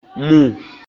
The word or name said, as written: Mù